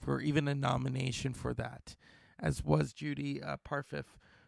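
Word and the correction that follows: Parfitt: How do you say her name? She was also, Judy was also in Girl with the Pearl Earring. Oh, such a great actress. She's still with us. – Judy Parfit.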